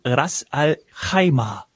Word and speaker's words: Ras al-Khaimah: Ein Hotel fehlt uns noch, und zwar das Rixos Bab al-Bahr in Ras al-Khaimah. Ich hoffe, ich habe das so einigermaßen klangvoll ausgesprochen. Was sagst du, Ines? – Perfekt.